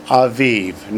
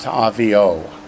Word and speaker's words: Aviv: Remember, and I said, well, his father, Aviv, not Avio. Okay. The first bullet under father column says, notice the Vav in his father is pronounced like a V and not the usual O.